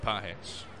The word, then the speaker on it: Páez: Andy Páez, spelled like pages, but pronounced Páez.